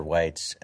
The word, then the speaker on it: Weitz: I'm your host, Peter Whites.